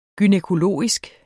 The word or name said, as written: gynækologisk